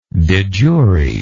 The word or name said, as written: de jure